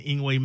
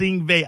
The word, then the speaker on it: Yngwie: Talking with guitar legend Yngwie Malmsteen, and there's a couple of different pronunciations. I grew up saying Yngwie, but I don't think that it's Yngwie. How do you pronounce your? Let's set the record straight for this. How do you say it? – It is Yngwie. – It is Yngwie. Okay, I'm good then. Okay, I'm talking to Yngwie. – Yeah, they like it's V, not W, because in Swedish and German W is V.